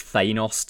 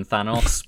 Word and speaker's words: Thanos: I will point out that for my entire comic book reading career until the films came out, it was Thanos to me rather than Thanos, but I recognise that I got that wrong.